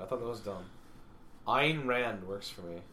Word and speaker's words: Ayn: Ayn. – Yeah, it rhymes with mine.